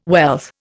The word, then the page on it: wealth: Indian